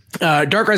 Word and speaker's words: Darkrai: Darkrat. Are you, well, okay. I can't tell if Calder in the chat is making fun of my pronunciation of Darkrai. Yeah, okay. No, he was. Thank you, Calder. Darkrai is another great one.